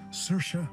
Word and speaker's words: Saoirse: So please, remember, it's Saoirse Ronan, not She Sells Seashells Ronan.